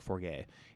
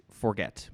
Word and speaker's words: Forget: Forget. I hope I'm pronouncing that right, and it's not French like forget. It's forget. Matt Forget.